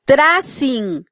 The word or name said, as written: trasin